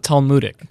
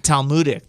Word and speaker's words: Talmudic: Talmudic. – Talmudic.